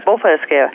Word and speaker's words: bofællesskab: It's something like bofællesskab. – That's right, bofællesskab.